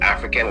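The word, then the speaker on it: Afriken: So every time you hear the term African when we're speaking, it's spelled A-F-R-I and then Ken, K-E-N.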